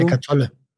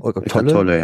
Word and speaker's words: Eckhart Tolle: Ich weiß nicht, ob ich den nochmal richtig ausspreche, aber ich glaube, der hieß Eckhart Tolle. Heißt der so? – Eckhart Tolle. – Mhm. Eckhart Tolle, ja.